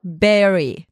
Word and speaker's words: bury: Bunu bury diye söyleme eğiliminde bulunuyoruz. Ud olduğu için orada. Bunun doğru telaffuzu bury.